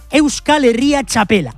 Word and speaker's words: Euskal Herria Txapela: a ver si lo digo bien a la segunda, final de la Euskal Herria Txapela. Ahora sí lo he dicho bien, soy un crack, Urkabe.